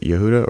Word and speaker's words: Yehuda: I don't know if that's how you say his name. Is it Yehuda or? – Yeah, Yehuda.